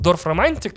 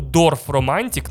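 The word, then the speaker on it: Dorfromantik: Очень рекомендую Дорф Романтик на конце Кей. Дорф Романтик на конце Кей. Как пишется, так и слышится, это же немецкий.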